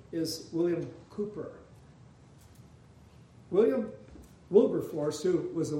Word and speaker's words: Cowper: And it's spelled Cowper, but it's pronounced Cooper.